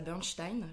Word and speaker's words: Bursztein: Aujourd'hui, je reçois Rosa Bernstein. Je l'ai bien prononcé. – Parfait. Super.